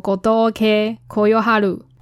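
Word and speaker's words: 吾峠呼世晴: 那个无卡忽视情全名叫做 kodoke koyoharu。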